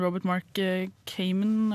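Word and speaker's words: Robert Mark Kamen: Og da blir det andre boller, fordi han, eh, han som har lagd den, han Robert Mark Cayman, er det sånn du uttaler det? Ja. Mm.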